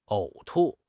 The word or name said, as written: 呕吐